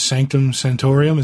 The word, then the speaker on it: Sanctum Sanctorum: It was a backup story in the Gambit about Gambit's father, who's a thief, and he was breaking into Doctor Strange's Sanctum Sanctorum.